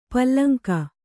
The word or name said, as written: ಪುಲ್ಲಿಂಗ